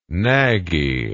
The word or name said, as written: knaggy